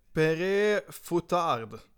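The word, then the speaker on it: Père Fouettard: spänning på det korrekta uttalet. – Ja, eller hur? Eh, och det är någonting i stil med Pere Foutard.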